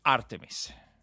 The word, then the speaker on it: Artemis: Allora, devo prendere la pronuncia, visto che è un programma di origine principalmente statunitense al quale partecipa l'Europa e anche la Svizzera, Artemis. – Artemis. – All'inglese, Artemis.